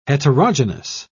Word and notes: heterogenous: British English

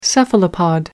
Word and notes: cephalopod: American English

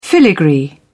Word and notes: filigree: British English

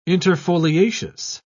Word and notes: interfoliaceous: British English

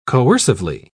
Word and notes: coercively: British English

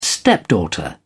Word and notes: stepdaughter: British English

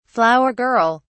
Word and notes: flower girl: British English

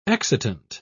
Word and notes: excitant: British English

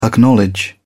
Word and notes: acknowledge: British English

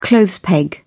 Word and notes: clothes peg: British English

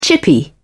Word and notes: chippy: British English

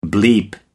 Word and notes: bleep: American English

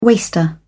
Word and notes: waster: British English

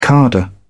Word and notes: cadre: British English

